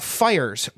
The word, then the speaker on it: Fyre: It is the fire drill. – It is the fire drill? Okay. – It's called the fire drill. It's spelled F-Y-R-E, but it is the fire drill.